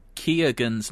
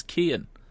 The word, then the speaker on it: Keoghan: Perry Keoghan's name as Kean. His surname is actually pronounced Keoghan. I'd always said Kean.